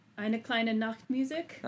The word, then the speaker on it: Eine kleine Nachtmusik: Eine kleine Nachtmusik.